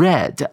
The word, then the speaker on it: read: 注意到这个 read 呢，它是一个过去分词，这边要念成 read 啊，r e d 的发音哦。